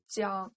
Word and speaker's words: Jiang: It's not G. It's G. It's pronunciation is like G. It's not J. – G. – Jiang. J in Chinese, its pronunciation is similar to G in your G. English.